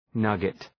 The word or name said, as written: nugget